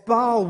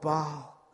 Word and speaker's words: Baal: the god of Baal, or Baal, as I'll use interchangeably because people use different pronunciations for it.